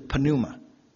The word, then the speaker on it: pneuma: 旧约希伯来语呢是用 ru'a 这个字，那新约是用 pneuma，我们英文念成 pneuma，不过希腊文念法是 p 要发音的，是 pneuma。